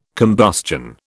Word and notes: combustion: British English

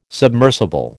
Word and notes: submersible: American English

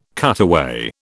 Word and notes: cutaway: British English

